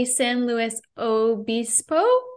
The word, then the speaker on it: San Luis Obispo: He played and attended Cali Poly San Luis Obispo.